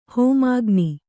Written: ಹೋಮಾಗ್ನಿ

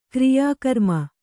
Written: ಕಾರ್ಯಕ್ರಮ

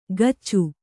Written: ಕಚ್ಚು

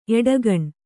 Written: ಎಡಗಣ್

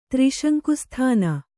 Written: ತ್ರಿಶಂಕುಸ್ಥಾನ

